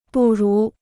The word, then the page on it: 不如: bù rú